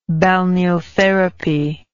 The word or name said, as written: balneotherapy